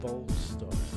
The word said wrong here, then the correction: Baldock: I'm trying to pronounce it. I'm struggling. – Baldock. No pressure. Yeah, Baldock. Give you George Baldock.